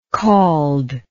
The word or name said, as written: cauld